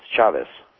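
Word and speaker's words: Chávez: One thing on, real quick, the Canadian lady that called in, Chavez's name is pronounced with a hard CH like cheese, and there's no Z sound like the English buzz in Spanish. It's an S. So it's Chavez.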